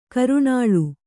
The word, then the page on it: ಕರ್ನಲ್ಲು: karnallu